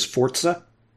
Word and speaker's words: Forza: So, it's pronounced Forza because it's Italian.